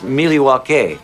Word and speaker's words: Milwaukee: Actually, it's pronounced Milwaukee, which is Algonquin for the good land.